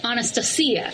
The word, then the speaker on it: Anastasia: And my husband, he speaks Russian, so he would tell you that the proper way to say this is Anastasia.